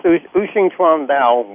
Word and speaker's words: Wu Xing Chuan Dao: And let's continue with Wu Xing Chuan Dao. I think that's how you say it. – Yes. Yes, Wu Xing Chuan Dao,